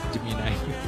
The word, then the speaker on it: Gemini: Gemini.